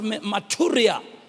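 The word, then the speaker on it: maturia (μαρτυρία): So that word witness, Barcelona, is the word, if you want to write it down, it's spelled M-A-R-T-U-R-I-A. If you want to pronounce it, it's pronounced maturia. Maturia. M-A-R-T-U-R-I-A. And that word means evidence.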